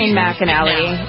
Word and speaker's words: McAnally: Who's this? McAnally? How do you pronounce Shane McAnally.